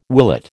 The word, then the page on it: willet: American English